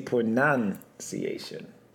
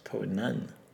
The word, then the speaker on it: pronunciation: Because most people say pronunciation. It's pronun.